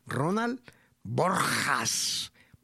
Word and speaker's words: Ronald Borjas: Ronald Borjas.